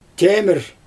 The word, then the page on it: chejmIr: Xyzyl